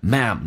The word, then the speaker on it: ma'am: OK,Mam, 不要读成 Mom 就行了。 No, Mam,Mam 就是一个 Madam 的一个缩写。 Yes, Madam, 那个 Madam, 缩写成 Mam, No, Mam, but I hate to see you standing there all by yourself.